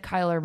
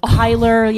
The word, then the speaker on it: Kyler: I kept saying his name wrong. – I know. – I thought it was Kyle. – Well, it's the kind of name that someone needs to correct you on.